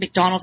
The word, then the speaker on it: McDonald: McDonald is spelled M-C-D-O-N-A-L-D.